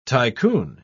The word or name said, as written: tycoon